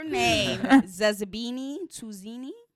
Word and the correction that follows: Tunzi: Oh, boy. – Tunzi.